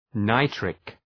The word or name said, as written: nitric